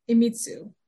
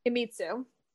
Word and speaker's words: Iemitsu: Uh, Tokugawa Itsumi, Iemitsu. Iemitsu.